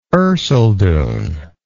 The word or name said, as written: erceldoune